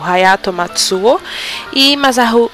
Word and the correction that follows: Masaharu: Masaharu, Masaharu Iwata. Agora falei certo.